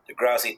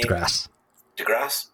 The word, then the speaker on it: DeGrasse: DeGrasse Tyson. However you pronounce the man's name. – DeGrasse? – Perfect.